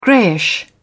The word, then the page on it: greyish: British English